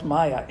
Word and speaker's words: maya: Everything in the world is maya. It's pronounced maya.